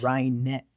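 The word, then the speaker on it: Rinek: Reinick. It's Reinick. – Reinick? – Like I'm gonna wring your fucking neck if you don't say it right.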